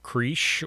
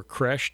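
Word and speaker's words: crèche: and you picture the creche or creche, depending on your pronunciation of it, that which is the depiction of the manger.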